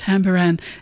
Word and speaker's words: Tabaran: It's spelled T-A-B-A-R-A-N, but pronounced Tambaran. It means spirit house, and that's George Telek, T-E-L-E-K, a Papuan musician, there with Not Drowning Waving, David Bridey and Not Drowning Waving, from back in the 90s, actually.